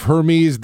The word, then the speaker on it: Hermes: Rolling tonight uptown, the crew of Hermes, D'Etat, and Morpheus. Did I just butcher that, Jeff? – No, you got it.